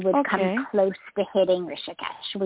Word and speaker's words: Rishikesh: Rishikesh. Yeah, it's three syllables, Rishikesh. – Okay. – Yeah, Rishikesh. R-I-S-H-I-K-E-S-H.